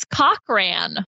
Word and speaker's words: Cochran: Where is Cochran? – Oh, boy. – B, why did you pronounce it that way? How is that spelled? – C-O-C-K space there we go. R-A-N.